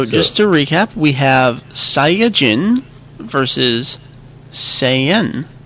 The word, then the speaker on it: Saiyajin: the correct pronunciation, Super Saiyajin. – He does. – That is the Japanese pronunciation.